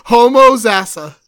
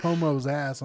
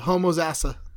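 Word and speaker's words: Homosassa: It's Homo Zassa. – Okay, it sounds like you said Homo's ass. I'm like, that's it is. Funny. Homo's ass, and then there's a letter A. That's how it's spelled. Homo Zassa.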